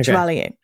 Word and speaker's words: Chevalier: I'm going to butcher this last Chevalier? Name. – Yes. – Okay. Chevalier.